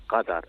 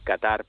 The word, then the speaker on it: Qatar: Ustedes nos empeñamos en pronunciar como arabistas que somos y especialistas, ¿no?, en esa región, pues tal y como lo pronuncian ellos mismos, ¿no?, que es Qatar, ¿no? Es cierto, ¿no?, que en español la Real Academia ha optado por ponerlo con C, el Qatar, ¿no? Por eso, pues muchas veces, pues la gente viene a decir Qatar, pero bueno, en árabe la pronunciación correcta sería efectivamente Qatar.